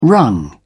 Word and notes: wrung: British English

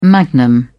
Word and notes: magnum: British English

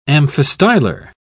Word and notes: amphistylar: British English

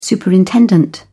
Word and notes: superintendent: British English

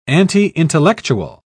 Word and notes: anti-intellectual: British English

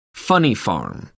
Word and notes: funny farm: American English

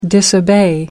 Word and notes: disobey: American English

